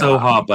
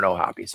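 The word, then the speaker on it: O'Hoppe: I don't know if it's Ohap or Ohapi, but I've heard people say it's Ohap, I believe. – I think it is too, but I've heard Ohapi, so I don't know. And Jonah Heim, who you've slandered. Oh, pronunciation. Ohapi. – Oh, Ohapi. Okay, there we go.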